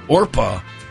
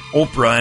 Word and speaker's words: Orpah: Oprah Winfrey shared that on her birth certificate, her given name is actually Orpah. From the Bible's story of Ruth. It's O-R-P-A-H. Orpah? Yeah. But relatives kept mispronouncing it Oprah, and it just stuck.